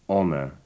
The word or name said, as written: honour